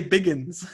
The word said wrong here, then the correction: Bagans: Did I? It's Bagans.